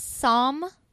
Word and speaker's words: Psalm: His name is Psalm West. – Yep. – The P is silent. – The P is silent. So it's Psalm, like the book of Psalms.